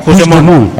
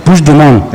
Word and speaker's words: Puigdemont: No, Puigdemont. – Vale. Puigdemont. – Eso es. – Sí, lo dices vale. Como francés, así.